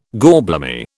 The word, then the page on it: gorblimey: British English